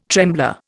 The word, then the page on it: trembler: Indian